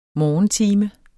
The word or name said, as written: morgentime